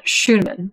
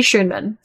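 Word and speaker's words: Schuneman: Lee Schoonerman, I think that's how you say his name. No, there's no R in it. Schoonerman. Lee Schoonerman.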